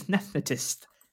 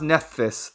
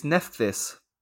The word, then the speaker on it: Nephthys: Yeah, it's pronounced Nephthys, they said. – Nephthetist. – No, not Nephthetist. You're putting a T in, it's Nephthys.